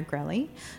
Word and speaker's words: Grelly: But I want to start with, first, I have Meredith Meyer Grelly, who is in studio. Did I say your last name correctly? – You did. It rhymes with smelly.